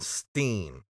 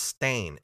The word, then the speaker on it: Berenstain: When I was a kid, it was E-I-N, Steen. Yeah. And I will go to my grave believing that. – And now it's spelled different? – Yeah, it's Stain, S-T-A-I-N. It was never Stain, it was Steen.